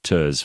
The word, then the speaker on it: TERS: I mean, we had to do it as well, and we managed to get, I think, 2 months of TERS for everybody on our staff. But, you know, it's rhymes with turds. – It is like turds, but, you know, thankfully it helped a little bit.